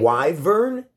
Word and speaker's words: wyvern: I can't pronounce this word. – Wyvern. – Wyvern. Wyvern? – Yeah. – It's the it's spelled W Y V E R N.